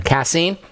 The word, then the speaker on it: casein: Are you familiar with casein? Or am I saying this right? – Yes, casein.